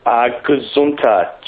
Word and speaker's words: Gazunta: Gazunta, G-A-Z-U-N-T-A. Gunta. So or for the American pronunciation, Gazunta.